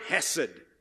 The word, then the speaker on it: hesed: It is the word hesed.